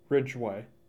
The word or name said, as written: Ridgeway